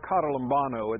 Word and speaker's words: katalambano: The word comprehend is the word katalambano. It's spelled this way, K-A-T-A-L-A-M-B-A-N-O. Again, K-A-T-A-L-A-M-B-A-N-O, katalambano.